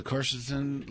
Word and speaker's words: quercetin: It's spelled Q-U-E-R-C-E-T-I-N. – Quercetin. Right.